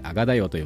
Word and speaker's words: AGADAYO: えー、このポッドキャスト、今おしゃべりしたのは、 AGADAYO と書いて、あがだよと読みます。